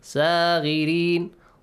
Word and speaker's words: صاغرين: Also صا has a heavy sound. صاغرين. Do not pronounce it. صاغرين.